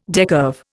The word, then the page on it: decurve: Indian